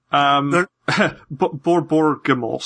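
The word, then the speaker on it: Borborygmos: Borbor Gamos.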